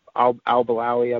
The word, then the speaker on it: Al Balawi: And Tom, correct me on the pronunciation, Balawi, Al Balawi, I believe is how you say it.